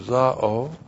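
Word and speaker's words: zao (ζάω): Z-A long O, zao.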